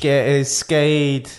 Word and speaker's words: Skeid: They came from 1-0 down at the weekend on Sunday to beat Skeid or Sked. I don't know how you pronounce it. – Skeid. It's S-K-E-I-D.